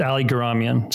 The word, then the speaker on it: Geramian: you know, you did it better than I do sometimes, but yeah, it's Ali Geramian. So just a hard G, not a, yeah.